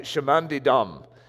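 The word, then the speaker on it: Chemin des Dames: What is it? Dame. Chemin de Dame. See, I'm speaking English when I say Dame. Chemin de Dame.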